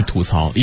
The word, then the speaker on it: 吐槽: 咱们先来规范一下它的读音。 – 应该是吐槽，因为我之前就是咱们学院那个基本功大赛，我第一个词抽到变音试音第一个词，我读的是吐槽，老师说错了，应该念吐槽，是吗？ – 理由呢？